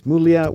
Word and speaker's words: Muglia: And just be sure that Muglia is spelled M-U-G-L-I-A, Muglia, the Italian, but he pronounces it Chris Muglia.